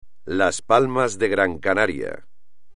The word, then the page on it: Las Palmas de Gran Canaria: espagnol